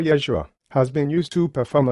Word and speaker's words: Yeshua: It is true that in Hebrew, the name is pronounced Yeshua or Joshua.